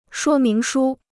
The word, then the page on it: 说明书: shuō míng shū